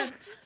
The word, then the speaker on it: pedant: You said people's pendant. – Oh, people's pendant. – As in a play on the word pedant? – Yeah, pedant.